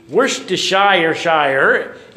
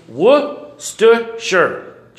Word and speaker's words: Worcestershire: because it looks like it should be Worcestershire. It's Worcestershire. Try it with me. Worcestershire. If you don't remember anything else today, next time somebody says, hand me the Worcestershire, you can say, it's Worcestershire.